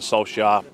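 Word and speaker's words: Solskjær: Skolstad. – The clarity is that Ole Gunnar Solskjaer has left his role as Manchester United manager. – Ole Solskjaer.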